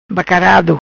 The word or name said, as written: Bacarado